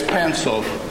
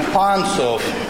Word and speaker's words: Pantsov: Yeah, most Americans and even some British people mispronounce my name as Pantsov. I'm not Pantsov, I'm Pantsov.